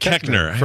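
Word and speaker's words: Koechner: David Kochner. Or Cochner, however you say his name. – Kechner. David Kechner.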